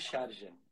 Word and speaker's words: Sharjah: Não sei se a pronúncia é Charja. Charja. – É, no Emirado de Charja. É.